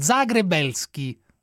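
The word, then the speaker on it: Zagrebelsky: Devi pronunciare, scusami, Zagrebeschi. – Zagrebeschi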